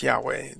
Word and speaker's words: Yahweh: YHWH, which is probably related to the verb to be and is usually pronounced as Yahweh, though it's I hesitate to pronounce it.